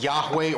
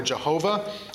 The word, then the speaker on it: Yahweh: This is sometimes pronounced Yahweh or Jehovah.